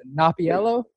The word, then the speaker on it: Napoliello: You said it rhymes with Jello Napiello? – Napoliello.